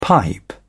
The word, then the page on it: pipe down: British English